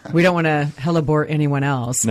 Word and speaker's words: hellebore: Okay, so I have to say, so a hellebore, am I saying that correctly? – Yes. – Hellebora or hellebore? – It's hellebore.